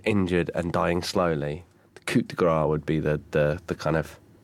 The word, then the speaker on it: coup de grâce: It doesn't end with an S. So it's not silent. It's grace. It's coup de grâce.